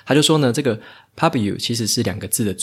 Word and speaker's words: Pubu: 在分享今天的书之前呢，来介绍一下本集节目的赞助厂商 Pubu。